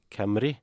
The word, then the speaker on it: Cymru: Uh, nemlig på et par bare uh, Altså den walisiske serien, som akkurat nå heter JD Camry Premier. Som vi har funnet ut at det omtrent er uttalen. På Camry? Mm -hmm. der, hvordan skrives Camry? C-y-m-r-u.